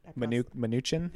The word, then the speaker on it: Mnuchin: And as Matt Gaetz points out, Ceri marries Steve, I'm going to pronounce that, Munchen. – Mnuchin? – There we go.